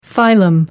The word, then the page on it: phylum: American English